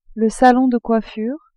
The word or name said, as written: salon de coiffure